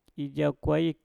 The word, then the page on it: ijauw kwaip: Mpur